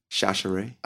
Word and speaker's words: Chachere: Chachere.